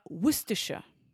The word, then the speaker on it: Worcestershire: She lives near, or lives in a county, I'm pretty sure it's a county, near Worcestershire.